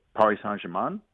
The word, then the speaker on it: Paris Saint-Germain: he's already signed with Paris Saint-Germain.